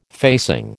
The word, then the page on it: facing: American English